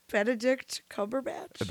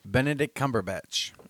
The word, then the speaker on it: Benedict Cumberbatch: Bender Cumberbatch. I don't know how to say his name. – Benedict Cumberbatch. Yeah, finish that whiskey. – Benedict Cumberbatch.